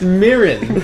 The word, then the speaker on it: mirin: Myron? – Myron. – Cooking sake? – It's pronounced Mirin.